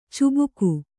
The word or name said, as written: ಚುಬುಕು